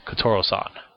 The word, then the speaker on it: Kotoura-san: Yes, the U is silent. I apologize. Kotoura-san.